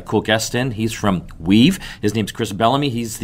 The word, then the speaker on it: WIIVV: So tell our listeners what Weave is all about. And it's spelled W-I-I-V-V.